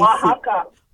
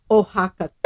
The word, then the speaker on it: Oaxaca: I can never pronounce it right. It's spelled O-A-X-A-C. – Oaxaca. – Oaxaca. Thank you.